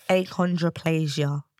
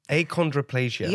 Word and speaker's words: Achondroplasia: Achondroplasia. – Achondroplasia.